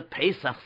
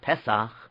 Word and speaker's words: Pesach: It was getting closer and closer to the Pesach season, which should be pronounced Pesach, but the most common way people say it is Pesach, so we're going to say the same way. But if you read the Nekudas correctly, it's Pesach.